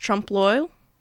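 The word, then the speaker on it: trompe-l'œil: Care to help me with the original French? – Yes, I believe it's pronounced Trompe l'oeil.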